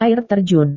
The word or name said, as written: air terjun